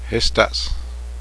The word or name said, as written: snow